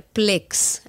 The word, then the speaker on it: Plex: Y tengo para finalizar Plex, así mismo como se pronuncia, P-L-E-X.